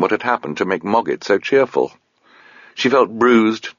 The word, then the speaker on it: Mogget: "The kings and queens of the Old Kingdom," pronounced Mogget, after some difficulty.